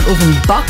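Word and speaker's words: Ofenbach: Nicht auf dem Bach, sondern auf dem Back.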